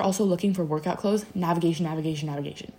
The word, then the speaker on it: NVGTN: Navigation, but it's spelled NVGTN.